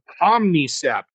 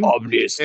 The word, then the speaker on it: Omnicept: It's about the HP Reverb G2, I'm going to say this wrong, Omnicept. – Yes, I said it wrong. – Edition. – Omnicept.